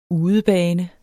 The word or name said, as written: udebane